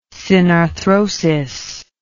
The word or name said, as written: synarthrosis